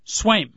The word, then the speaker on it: Sveum: Might be snuggling up a little bit, but how do you pronounce the last name? Is it Sveum? – Sveum.